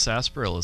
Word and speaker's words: sarsaparilla: I don't think I'm pronouncing correctly. – No, that's right. – It's pronounced sarsaparilla, Steven.